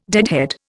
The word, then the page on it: deadhead: Indian